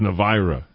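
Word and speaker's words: Navaira: I think we've pronounced this like five different ways today. But I've been told it's Navira, so.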